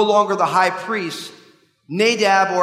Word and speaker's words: Nadab: But it would probably be more Nadab, because in Hebrew it's on the second syllable, one of the differences between the languages.